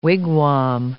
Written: wigwam

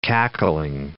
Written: cackling

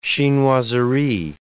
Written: chinoiserie

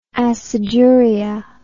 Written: aciduria

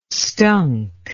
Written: stunk

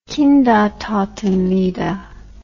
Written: kindertotenlieder

